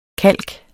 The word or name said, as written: kalk